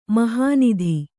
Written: ಮೆಹನತು

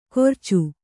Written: ಕೊರ್ಚು

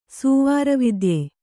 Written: ಸೂವಾರವಿದ್ಯೆ

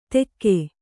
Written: ತೆಕ್ಕೆ

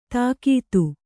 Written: ದಗದ

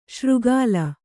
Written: ಶ್ರೀಕಲ

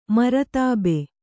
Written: ಮರ್ತಬೆ

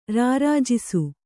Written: ರಾರಾಜಿಸು

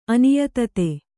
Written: ಅನಿಯತತೆ

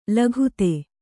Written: ಲಗತು

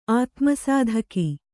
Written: ಆತ್ಮಸಾಧಕ

